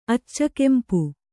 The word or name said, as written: ಅಚ್ಚಗೆಂಪು